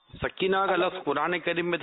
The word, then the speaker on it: سکینہ: یہ ہمارے یہاں معروف تو سکینہ ہے، ویسے سکینہ اصل تلفظ یہی ہے، یہ سکینہ اور چیز ہے.